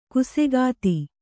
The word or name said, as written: ಖಾಸಗತಿ